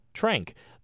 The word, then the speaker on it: tranq: There are acceptable words like QAT, pronounced cat, and TRANQ, or trank.